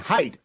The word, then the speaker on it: Haidt: I'm joined by Jonathan Haidt, H-A-I-D-T. I've been mispronouncing it, but I got it right there.